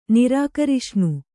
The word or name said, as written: ನಿರಾಕರಿಷ್ಣು